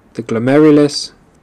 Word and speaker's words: glomerulus: これ、英単語では、 g l o m i r r l e s と発音されます。かなり聞き取るの難しいんですけれども、 mirrorless のような感じです。 – the glomerulus